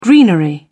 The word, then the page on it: greenery: British English